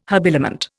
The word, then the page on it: habiliment: Indian